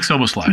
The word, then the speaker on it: Szoboszlai: Oh yeah, Dominik Szoboszlai.